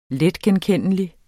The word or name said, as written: letgenkendelig